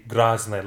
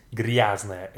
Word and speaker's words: грязная: But if you say грязная, like with the я, even though it's kind of a little bit incorrect in pronunciation, it's still better than to say грязная, like it's a letter. It's грязная, я, грязная.